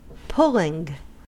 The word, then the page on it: pulling: American English